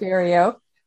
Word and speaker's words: vireo: Is that correct? Vireo? Is that how you say it? Yes, that's right. Red-eyed vireo.